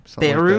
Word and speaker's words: Beru: B-E-R-U. How would you pronounce that? – Beru. – Beru?